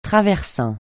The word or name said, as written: traversin